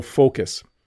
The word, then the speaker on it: focus: And then can you pronounce focus correctly? Because I see lots of sound like the F word, I'm going to call it. So focus.